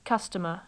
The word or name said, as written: customer